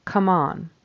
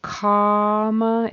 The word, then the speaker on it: Comma: So comma is a short O, come on is a short U in that first syllable.